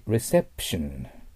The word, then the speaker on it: reception: 次、 reception。これは日本語で言うとレシーブ。だけど、レと言ったら L の発音なので気をつけてください。うの口にしてベロが下に落ちてないといけません。